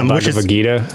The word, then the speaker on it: Bhagavad Gita: Yeah, Bhagavad Gita.